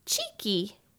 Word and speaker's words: Cicci: In Italian, her name is Cheeky. – That one I like. – C-I-C-C-I.